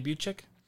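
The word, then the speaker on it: Boucek: I was pronouncing her name wrong. – I thought you said it right. – Buchek, right? Jenny Buchek? – Yeah.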